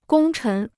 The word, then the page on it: 功臣: gōng chén